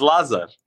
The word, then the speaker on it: Lazar: Can you say my name correctly once? – No, I cannot. What? Yeah, help me. Help a brother out. Help a brother out. – It's Lazar. – Lazar.